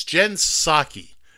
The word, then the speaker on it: Psaki: It's Jen Psaki. The P is silent.